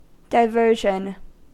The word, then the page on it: diversion: American English